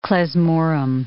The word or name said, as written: klezmorim